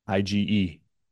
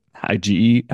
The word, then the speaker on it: IgE: IgE rhymes with allergy. IgE, allergy.